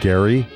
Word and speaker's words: Gerry: His name, it looks like it's spelled Jerry, G-E-R-R-Y, but he pronounces it Gary.